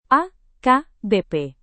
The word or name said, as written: akbp